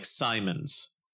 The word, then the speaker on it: Symonds: So if they have the name like Simons, it might be S-Y-M-O-N-D-S, and the D is silent, you know, so people can't just hear it and then try to spell it the way that they think it is.